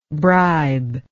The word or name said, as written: bribeable